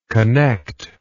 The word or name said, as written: connectability